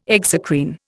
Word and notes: exocrine: Indian

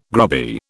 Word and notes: grubby: British English

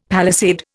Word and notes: palisade: Indian

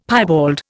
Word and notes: piebald: Indian

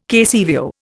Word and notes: KCVO: Indian

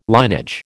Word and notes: linage: American English

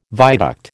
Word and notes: viaduct: American English

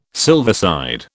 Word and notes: silverside: British English

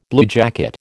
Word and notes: bluejacket: American English

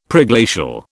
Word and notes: Preglacial: British English

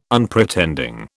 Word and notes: Unpretending: British English

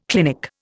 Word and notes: clinic: Indian

